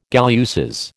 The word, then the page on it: galluses: American English